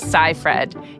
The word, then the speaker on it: Seyfried: The result is The Dropout, now streaming on Hulu and starring Amanda Seyfried. Yes, apparently that's the right pronunciation.